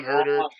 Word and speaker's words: Huerter: Kevon Huerter.